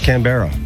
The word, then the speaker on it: Canberra: Yeah, it's Canberra.